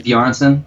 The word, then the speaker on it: Bjarnson: How do I say that? Finn Jameson? – Finn Bjarnson? – Yeah. – Yeah.